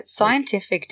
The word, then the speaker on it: SCNTFC: Like, Scientific did a really great job. – It's pronounced Scientific? – Yep, that's how it's spelled.